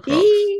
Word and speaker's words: clerks: He had three housemates who were all clerks, or clerks, whichever way you pronounce it. – Clerks, yes. Not clerks. They were all clerks.